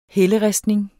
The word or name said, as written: helleristning